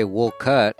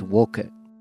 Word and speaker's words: Wolcott: Wolcott, not never, Walcott. Wolcott is on Route 15. It's in Lamoille County, I believe. You can say Wolcott, but if you really want to sound local, it's Wolcott.